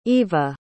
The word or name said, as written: evea